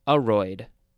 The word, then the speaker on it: aroid: That's A-R-U-M family. And aroid is also a noun. But no, it looks like it's more pronounced aroid. The first syllable is emphasized.